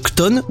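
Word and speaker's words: Chthon: Kton, ou Chton, selon qu'on veut le prononcer, qui est un ancien dieu primordial, qui est devenu un démon.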